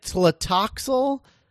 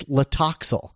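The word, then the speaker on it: Tlotoxl: Yeah, it's like Latoxel. Like most of the time. And then whenever William Hartnell pronounces it, it's Latoxel. Like the T is silent.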